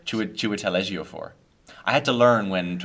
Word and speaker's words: Chiwetel Ejiofor: It's Chiwetel Ejiofor.